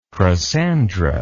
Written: crossandra

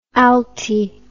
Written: alti

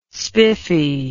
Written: spiffy

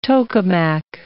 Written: tokamak

